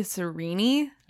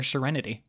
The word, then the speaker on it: Sarene: So, we have the story of Elantris with Sarene, Lady Sarene, how do we say her name? – When I was reading it, I was saying Serene. Serene. I believe from Brandon, it's supposed to be Serene.